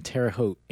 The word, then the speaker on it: Terre Haute: Don't see too much of that here in Terre Haute, Indiana.